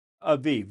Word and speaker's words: Abib: It was enacted annually during the Jewish month of Abib. Is that how you say it? – Aviv, yes. – Aviv. – Here it's two B's, but I think in the Hebrew it's... – It would be a V.